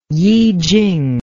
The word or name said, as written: yijing